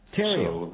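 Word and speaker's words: Theriot: It's pronounced Theriau. – Theriau. – So, like... Yeah, I tell people, it's like Ontario without the on, and that kind of helps.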